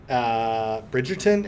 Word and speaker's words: Bridgerton: For shows, I haven't watched this, but the big trend is Bridgerton. Did I say that right? Yeah.